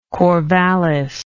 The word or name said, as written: corvallis